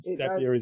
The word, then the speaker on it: Zapier: Zapier makes you happier, they say. – Yes. So that's, Zapier is actually the right pronunciation.